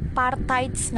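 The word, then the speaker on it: Parthayajña: nah Partites ya tulisannya tapi dibacanya Partites nah tulisannya ya uh, Kunjara Karna dan Partites nah P A R T H A Y C N A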